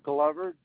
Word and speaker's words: Glover: Leonard, L-E-O-N-A-R-D. Glover, G-L-O-V-E-R.